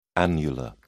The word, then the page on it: annular: British English